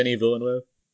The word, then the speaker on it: Denis Villeneuve: Denis Villeneuve? – Yeah.